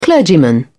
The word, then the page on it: clergyman: British English